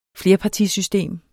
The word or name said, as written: flerpartisystem